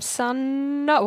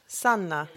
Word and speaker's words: Sanna: Hello, I'm Sanna. Why am I saying it like that? That's how you actually pronounce it. – Sanna.